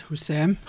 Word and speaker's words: Houssem: Yes, Houssem.